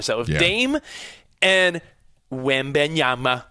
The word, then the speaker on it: Wembanyama: And yeah, Danny G, I know Wembanyama is a work in progress. He's not even able to drink alcohol. He's a, you know, 7'5", 19-year-old kid. – How do you say it? Wembanyama.